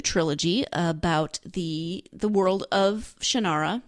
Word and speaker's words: Shannara: I don't know how he pronounces it. I grew up pronouncing it in my head, Shannara. The audiobook narrators all say Shannara.